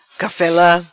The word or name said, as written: Cafela